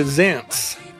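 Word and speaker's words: Zaentz: Is it like all the Zaints go marching in? – I think it's closer to Zaintz.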